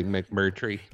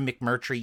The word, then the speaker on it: McMurtry: it's an effective like plot device that Larry McNurty, I can never say his name. – Larry McMurtry. – Yes, exactly. Larry McMurtry uses, because he does this in a lot of his stories.